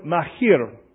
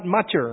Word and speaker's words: Machir: Try the word Machir. And what you're trying to say is not Macher or Macher. You're kind of pronouncing the C and the H, Machir.